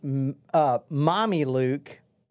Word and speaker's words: Mameluke: So anyway, he came up with his hedge fund and called it Mommy Luke. Mommy Luke. – Can you spell that, please? – M-A-M-E-L-U-K-E.